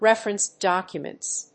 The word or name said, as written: referenced documents